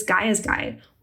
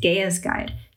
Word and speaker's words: Gaia's Guide: From the 1970s to the 1990s, there were women's travel guides such as Gaia's Guide, or pronounced Gaia's Guide, depending on who you're talking to.